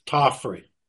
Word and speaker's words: Tofferi: It's Tofferi.